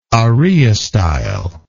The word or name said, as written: araeostyle